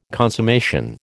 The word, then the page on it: consummation: American English